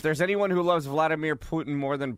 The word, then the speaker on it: Putin: So this is in 2016 Vladimir Putin pulled Steven say it right. – Right. Putin. He pulled Steven Seagal aside and said, "You're now a Russian citizen.